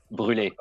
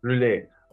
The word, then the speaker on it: brûlé: You have to help me in the pronunciation of French. – Brûlé, yes. – Brûlé, okay.